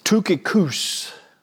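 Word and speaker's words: Tychicus: Tukikus is how that name is actually pronounced in the Greek.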